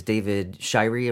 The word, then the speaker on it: Shirey: And your chaplain that week is David Shirey, am I pronouncing that correctly, from Lexington, Kentucky. – That's right.